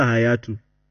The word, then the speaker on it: Hayatou: Hayatou. – Yeah, Isa Hayatou.